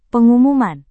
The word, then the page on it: pengumuman: indonesian